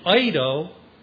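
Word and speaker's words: eido (εἴδω): Hooper is a word that means above, beyond, and eido, it's spelled like E-I-D-O, eido is a word that means know, or look.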